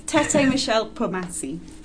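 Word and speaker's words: Tété-Michel Kpomassie: It's Tete Michelle Pomasi. I think that's as close as I'm going to get.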